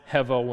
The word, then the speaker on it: hevel: The Hebrew is hevel. It's a H-E-B. It's pronounced hevel when you say it in English.